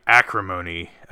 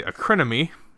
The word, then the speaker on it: Acrimony: Anyway, we're reviewing tonight Acrimony, or as Jess pronounces it incorrectly, Acrinomy. It's not spelled that way, or even close.